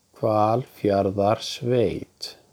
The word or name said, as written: Hvalfjarðarsveit